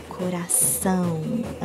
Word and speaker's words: coração: So instead of the H, you add an S. So coração.